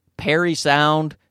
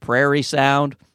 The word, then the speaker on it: Parry Sound: Now, I know I don't really think Perry sound is pronounced prairie sound, but I said it anyway. I don't know what happened. I have a trouble with it because it's spelled P-A-R-R-Y sound. So really it should be Parry sound, but they pronounce it Perry sound as far as I know.